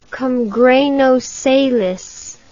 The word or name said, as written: cum grano salis